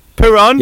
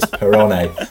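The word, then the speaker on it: Perrone: In this new City Report Spanish mini segment, you pronounce every vowel. You pronounce all the vowels, so it's Perone.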